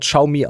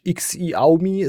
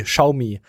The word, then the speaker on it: Xiaomi: Es wird Xiaomi ausgesprochen, nicht X-I-Aumi, es wird Schau. Wie Schaum und I, Xiaomi, hat das ausgesprochen.